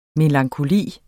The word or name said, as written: MELANKOLIEN